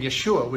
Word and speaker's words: Yeshua: Now, you noticed, of course, the name Yeshua was used. This is the Hebrew word for Jesus. It's the way the name Jesus would have been pronounced in Aramaic where he lived, at the time he lived.